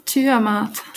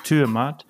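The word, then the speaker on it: Turmat: Warst du vegetarisch unterwegs oder hast du tatsächlich auch von Real Termat wie wird es richtig ausgesprochen? – Ja, Türmat. – Türmat. – Ja, sehr gut.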